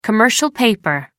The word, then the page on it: commercial paper: American English